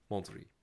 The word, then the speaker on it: Monterey: Oh, zeg ik dat goed? Ja. Monterey.